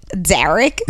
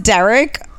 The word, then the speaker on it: Darrick: This guy spells it D A R R I C K. Derek. – Yeah. – But it's pronounced Derek.